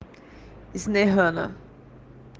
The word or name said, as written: shehana